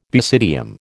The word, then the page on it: basidium: American English